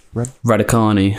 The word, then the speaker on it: Raducanu: Raducanu. – Raducanu.